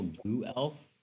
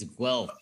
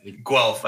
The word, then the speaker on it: Guelph: Oh, and the place that you're from, not actual Goo Elf. It's Guelph, apparently. – Guelph. I just didn't spell that very well for the pronunciation. – We were talking in the Discord, how to pronounce that, and Goo Elf came up as one of the ways of attempting to state it non-verbally. So. – Yeah, I didn't think of the W.